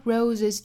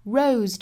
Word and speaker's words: rose: 那还要注意后面这个 red roses， red 尾部的这个 d 的发音我们是可以略掉的，但是要憋这么一下哈，要摆这个口型的 red roses too。 rose 这个词本身呢尾部是一个浊辅音，所以不要发成 rose，应该是 rose rose。